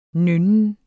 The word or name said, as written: nynnen